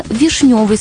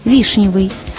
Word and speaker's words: вишнёвый: И еще, сегодня правильно вишневый сок, вишневое варенье, вишневый сад, а устаревшее ударение – вишневый.